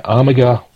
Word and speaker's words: Armiger: Was it Ormega? Well, I probably said that wrong, but looks nice. – Um, yeah, it's just the armor. Yeah, Armiger Glaze.